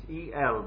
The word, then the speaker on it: El: It's E-L, but apparently it's got a long E and it ought to be pronounced El.